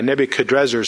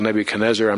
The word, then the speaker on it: Nebuchadrezzar: Nebuchadrezzar is actually the proper pronunciation, but so many of us are used to the classical Nebuchadnezzar, I'm not going to try to, you know, correct myself.